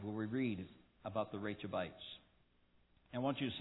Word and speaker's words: Rechabites: Whenever I say it, I'm going to think my wife said it's like Rachel, all right? Rechabites.